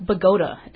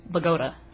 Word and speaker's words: Bogota: I think it's actually pronounced Bogota, as I've heard. – Really? – Yeah. – In Colombia, they say Bogota, but this is not Bogota. This is Bogota.